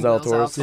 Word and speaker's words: Zalatoris: Zalatoris.